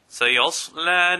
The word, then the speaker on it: سَيَصْلَى: Here the sword is full mouth, but be careful that you do not pronounce the lam full mouth at the same time. Some people pronounce it سَيَصْلَى. And this is incorrect. The only lam that is pronounced full mouth is the lam of Allah subhanahu wa ta'ala. So this lam will be pronounced with an empty mouth.